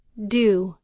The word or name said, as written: due